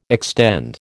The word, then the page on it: extend: American English